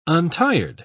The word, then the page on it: untired: British English